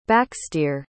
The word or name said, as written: backstere